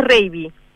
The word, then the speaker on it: Raby: Lo dijiste, sí, un poquito mal. Es Reiby.